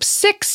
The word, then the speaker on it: CIX: But on in July, the boy group CIX debuted and changed pronounced my life. C-I-X. – Oh, it is pronounced C-I-X? – She okay.